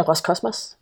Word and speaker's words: Roskosmos: Øh, som nævnt, så skal vi i dag tale om øh, det øh, russiske rumfartsagentur, eller rumfartsorganisation, øh, Roskosmos, Øh, hvordan udtaler du? Jeg synes, du udtaler lidt anderledes. Hvordan, øh, hvordan siger du det? Øh, jeg siger Roskosmos. – Nå, det var stort set det samme.